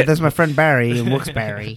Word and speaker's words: Wilkes-Barre: People from Wilkes-Barre, my friends there say is pronounced Wilkes-Barre. And that sounds wrong.